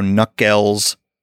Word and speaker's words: Nuckels: So Knuckles Music, no K on the front, .com is my website, and you can check out what new music is coming out there.